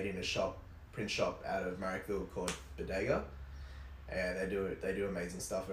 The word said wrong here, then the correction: Bottega: I don't mispronounce that. Bottega. Bottega.